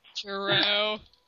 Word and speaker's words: Giroux: And they talked about the tour, and they said that him and Matt were like the studs of the tour, but they said his last name wrong. – Matt Giroux. – Totally lost. – Giroux. Yeah.